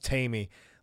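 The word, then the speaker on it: Taime: Spelled like Jamie, I think it's supposed to be a play on Tie Me Down, but then it's pronounced Tamey.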